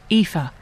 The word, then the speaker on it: Aoife: Aoife. Pronounced Aoife.